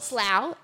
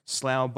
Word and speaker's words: Slough: Slough and Borough after each other pronounced differently is just enraging. And knowing that the Slough can also definitely be pronounced as Slough, just not in this place name instance.